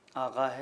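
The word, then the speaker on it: آقا: آغا کا لفظ استعمال ہوتا ہے. درست ہے کہ اس کا جو املا ہے وہ قاف کے ساتھ ہے مگر تلفظ جو ایرانی ہے وہ اس کا آغا ہو جاتا ہے.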